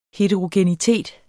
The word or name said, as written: heterogenitet